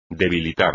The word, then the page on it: debilitar: espagnol